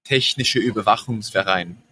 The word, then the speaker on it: Technische Überwachungsverein: I'll ask you how would we say it properly? In Technische Überwachungsverein Rhineland.